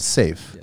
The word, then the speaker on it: Seaf: And safe. – Yes. – But it's spelled S-E-A-F, right? – Yeah, safe in Arabic stands for sword. So it's not, swords? – Yeah, it's not safety.